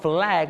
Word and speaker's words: flag: ออกเสียงคำว่า flag มี l ชัดเจน